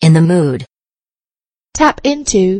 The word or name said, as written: in the mood